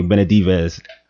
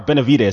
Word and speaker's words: Benavidez: TKO and Jose Benavidez. – Oh, you said it wrong. That's a tough last name. – Benavidez. Oh, sorry. Benavidez.